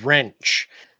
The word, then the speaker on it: wrench: Oh, you said wrench. – Yeah. Sorry. Yeah. The W is silent and the R is not.